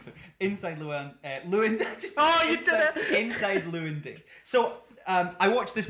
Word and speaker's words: Llewyn: The film is Inside Llewyn Davis, not Llewellyn Davis.